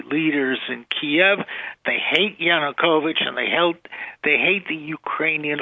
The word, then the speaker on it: Yanukovych: Or don't tell me they're protesting that badly for a trade deal, or they're just there against, this is their opportunity to oppose Yanukovych, I can't say it right. – Yeah, no, that's right.